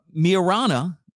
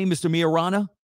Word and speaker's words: Miorana: However, Mr. Marion uh, Miarana, Maybe that's how you say it M I O R A N A, Mr. Miorana.